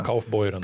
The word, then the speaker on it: Kaufbeuren: och gjorde 15 mål för Kaufbach Kaufbeuren, Kaufbeuren, hur uttalar det på tyska? Kaufbeuren. Kaufbeuren i tyska ligan.